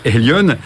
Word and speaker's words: Haleon: Alors vous êtes le président euh, France de, euh, de ça s'écrit H-A-L-O-N, mais j'espère que les Français vont bien le prononcer. Elion, euh, c'est la contraction de, deux mots, c'est ça Oui, c'est Health en anglais, la santé, et Leon, le lion.